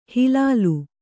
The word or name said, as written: ಹಿಲ್ಲೋಲ